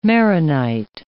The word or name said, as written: Maronite